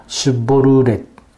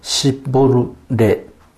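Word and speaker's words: シュ ボ ル ーレ (שיבולת): 同じ民族なので外見だけでは区別がつかないので、ギルアデ人たちはエフライム人を見分けるためにヨルダン川を渡ろうとする人たちに川を意味する単語シュボルーレを発音させました。 SH を S と発音してシッボルレと発音した人は殺されますが、その時に殺された人はおよそ 4 万 2000 人に上りました。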